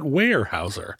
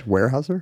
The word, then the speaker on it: Weyerhaeuser: Weyerhaeuser? – Weyerhaeuser, yeah. – It's got a Y, though. – Yeah, but it's... – But don't say it's a silent Y. – Don't say the Y.